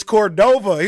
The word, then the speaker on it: Cordova: And you kept calling it Rancho Cordovo. – Oh, yeah. – And then an hour in, people were like, it's Cordova. He's like, yeah, I've been saying it wrong the whole time.